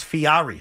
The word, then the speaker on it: Fieri: Guy Ferrari. Who said Ferrari. – That? That's not how you pronounce it. It's Fieri.